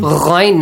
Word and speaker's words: Ghain: Ghain, not Ain. Ghain, the brother.